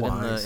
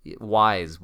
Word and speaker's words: Ys: This past week I've been I've been easing into Ease, or Is, I think, is how they kind of pronounce it Wise. – in the voiceover. Is Wise. Y apostrophe S. Wise. Ease Origin,